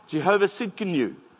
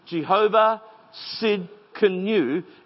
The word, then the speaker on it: Jehovah Tsidkenu: Jehovah Tsidkenu. It's got a silent T. Jehovah Tsidkenu. It means God, my righteousness.